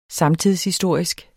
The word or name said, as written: samtidshistorisk